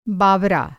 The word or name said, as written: बावरा